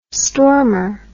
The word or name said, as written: stormer